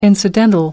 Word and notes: incidental: American English

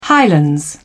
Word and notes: highlands: British English

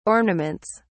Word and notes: ornaments: British English

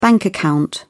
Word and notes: bank account: British English

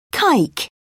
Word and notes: kike: British English

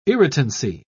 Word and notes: irritancy: British English

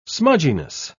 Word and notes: smudginess: British English

